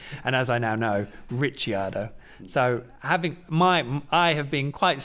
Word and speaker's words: Ricciardo: And I actually checked the Italian pronunciation of his name because, you know, people like Christian Horner have been calling him Ricciardo, and I checked it, and yes, it should be Daniel Ricciardo.